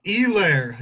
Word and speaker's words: Helaire: Helaire. Yeah, the silent H. That'll get you every time.